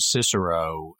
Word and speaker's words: Cicero: I like that they pronounced her name Cicero, the classic English pronunciation of that Latin right. name, instead of forcing on it the German-esque modern Cicero pronunciation.